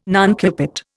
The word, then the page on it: nuncupate: Indian